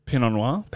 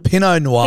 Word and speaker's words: Pinot Noir: I think it's more because of Pinot Noir. Pinot Noir. – Pinot Noir. – Yes. – Pinot. – Similar. – Pinot Noir.